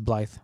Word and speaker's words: Blythe: Blythe or something like that, maybe. – I think you said it right. I think it is Blythe.